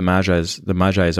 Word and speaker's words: Magi: I know that, but if you don't know what the Magi's are, and I'm not pronouncing that correctly. – Yeah, so, Magi, you got it.